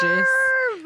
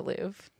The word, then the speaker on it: Louvre: I'm just making fun of how everybody on the internet can't say it. Okay. I know it's the Louvre.